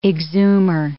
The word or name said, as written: exhumer